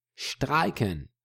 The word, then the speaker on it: streiken: Sounds very similar in German, streiken, but it shows us that whenever we've got an S in the beginning of a word in German, it is pronounced like sh.